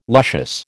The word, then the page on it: luscious: American English